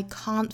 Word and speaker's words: can't: She may be the face I can't forget. 那我们讲到英音里面的这个 can't 这个词的元音应该是一个 R 的发音，所以是 can't, I can't forget.